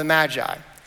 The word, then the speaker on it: magi: Now, I don't know if you know or not that that word is actually, I've just found out very recently, that word is actually pronounced magi. Now, in my uh, world growing up and everything that I've said, I've always pronounced it magi, but the, the official pronunciation is magi.